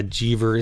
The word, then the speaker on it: Jever: It's like fever, but with a J. – Yeah, Jever.